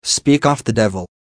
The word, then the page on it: speak off the devil: American English